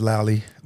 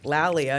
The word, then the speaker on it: Lalia: So I come from, my mother's name is Lally. My father's name is Willie. – And I always thought your mom's name, I remember one time you called me up. I was, cause it's spelled Lalia. – Yeah, yeah. – And I was like, Lalia. And you were like, who's that?